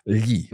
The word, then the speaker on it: gli: Well, with lo, that has a plural form and it's the one that's a little tricky to pronounce. It's gli.